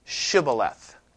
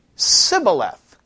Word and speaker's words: Shibboleth: If he said no, then he would say to him, say now the word Shibboleth. Do you remember that? Say Shibboleth. But he said, but then the, you know, the other guy would say Sibboleth. He couldn't pronounce the SH sound because he couldn't pronounce it correctly.